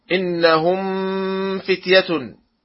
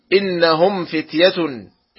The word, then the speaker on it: إنهم فتية: ومثال ذلك من يقرأ إنهم فتية وهذا خطأ، والصواب أن يقرأ إنهم فتية، إنهم فتية، والصواب نطق الميم من الشفتين بانطباق دون غنة.